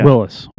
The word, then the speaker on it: Willys: So the S, it's Willys S and Willys. – Yeah. – Willys. Willys and, would be the right way to say it.